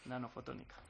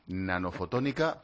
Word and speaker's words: Nanofotónica: Nanofotónica. – Nanofotónica, pero ojo, es solo teórico.